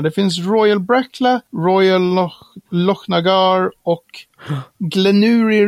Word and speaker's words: Royal Brackla: Nej, nu ska vi prata i tre minuter om eh, det här destilleriet som jag tror uttalas Royal Brackla.